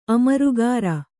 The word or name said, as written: ಅಮರುಗಾರ